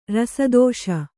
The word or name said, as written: ರಸದೋಷ